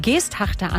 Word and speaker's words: Geesthacht: So, dann ist man in Hamburg und dann rufen die Geesthachter an und sagen, das heißt aber Geesthacht.